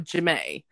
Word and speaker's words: Jamae: And one of the characters that he's created is a teenage girl called Jamae, which is actually spelled like Jamie, but she's that into herself that she calls herself Jamae.